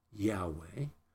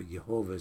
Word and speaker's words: Yahweh: And that name, Yahweh, or Jehovah, some pronounce it, which is not as accurate, means the ultimate reality separate above and beyond creation.